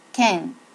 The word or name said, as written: ken